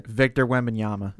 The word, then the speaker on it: Victor Wembanyama: Victor Wembanyama.